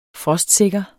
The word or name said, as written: frostsikker